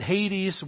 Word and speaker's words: Hades: The Greek New Testament term is Hades. We pronounce it Hades.